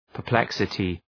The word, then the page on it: perplexity: pər’pleksətı